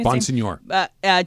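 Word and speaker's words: Bonsignore: And what I didn't see until just now, but this has been reported by Vinny Bonsignor, is that how I say his name? – Bonsignor.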